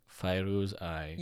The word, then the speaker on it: Fairouz Ai: I always forget how to pronounce her name. – Fairouz Ai. – Yes.